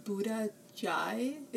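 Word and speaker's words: Buddha Chay: And then, speaking of Vietnamese, have you been to Buddha Jai? It's pronounced, or it's spelled C-H-A-Y.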